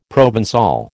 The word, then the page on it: Provençal: American English